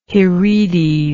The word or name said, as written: heredes